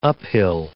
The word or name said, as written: uphill